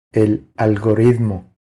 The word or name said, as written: El algoritmo